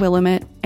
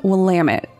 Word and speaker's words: Willamette: I said Willamette, and it is actually Willamette, so thank you to Melody for helping out with that one.